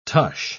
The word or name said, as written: tush